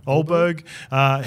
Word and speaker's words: Ulberg: Ulberg.